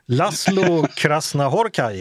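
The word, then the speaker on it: László Krasznahorkai: Laszlo Krasznahorkai heter han.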